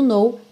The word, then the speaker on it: know: Olha que interessante você reparar que o know, o verbo saber ou conhecer, tem a mesma pronúncia do know, não. Então, embora ele tenha esse K no começo, N-O-W, o K não é pronunciado e ele não vai ter a pronúncia do agora, now, aberto. É uma pronúncia fechadinha, know, ok?